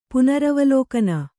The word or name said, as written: ಪುನರವಲೋಕನ